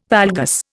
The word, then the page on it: valgus: Indian